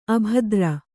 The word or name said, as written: ಅಭದ್ರ